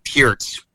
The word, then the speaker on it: Peart: Like him and Neil Peart from Peart. As yeah. you're supposed to pronounce it,